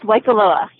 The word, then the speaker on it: Waikoloa: Waikoloa, Hawaii. Am I saying that right? – Aloha. It's Waikoloa, yes.